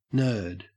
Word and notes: nerd: Australian English

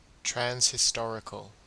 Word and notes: transhistorical: Australian English

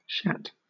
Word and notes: shat: British English

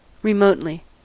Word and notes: remotely: American English

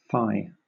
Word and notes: fie: British English

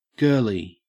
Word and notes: girlie: Australian English

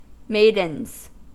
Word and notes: maidens: American English

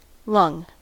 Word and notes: lung: American English

